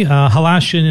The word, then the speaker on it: Halashian: Let me bring Kelly Halashian into the conversation. He told me, I was asking about the pronunciation of his name, and he said it rhymes with Colossians.